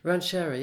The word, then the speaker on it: ranchera: But to me, there's something sensual in that poem, but more so in poems like, is it pronounced Ranchera? – Ranchera, yeah.